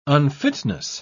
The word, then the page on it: unfitness: British English